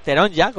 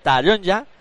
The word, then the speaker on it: Taronja: cuando digamos Taronja, no Teronja, como estaba diciendo en varias ocasiones, o sea, dejamos claro que la primera sílaba con A, Taronja, esa es la forma eh, correcta de decirlo, por supuesto.